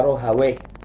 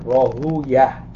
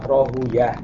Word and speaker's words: Rahawayh: Ini faedah para ulama berbeda cara membaca Rahawayh. Ya. Sebagian membacanya Rahawayh. Tapi kebanyakan para ulama hadis membacanya dengan Rahuyah. Mereka menghindari pengucapan waih, karena waih ini katanya ya min asma'i syaitan, termasuk nama syaitan. Jadi kalau ulama al-hadis kebanyakannya membacanya Rahuyah. Tapi ulama lughah Rahawayh, Ishaq bin Rahawayh. Ya kan bisa dibaca dua itu, Rahuyah, Rahawayh. Kita belajar ilmu hadis berarti apa? Rahuyah, Ishaq bin Rahuyah.